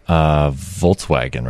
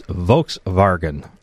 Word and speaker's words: Volkswagen: Volkswagen, right? – That's how you would think people would pronounce it. But apparently the correct pronunciation in Germany is Volkswagen.